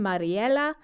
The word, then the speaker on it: Mariella: I finally suggested to his daughter that the name Mariella, with the Spanish pronunciation Mariela, would be a lovely tribute to both her grandmother and the great grandmother, Mary and Ella.